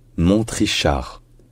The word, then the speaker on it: Montrichard: Donc, ce n'est pas Mont-Richard, c'est Mont-Trichard. Mont-Trichard.